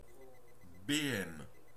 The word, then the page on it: béhème: /be.ɛm/; France (Normandie)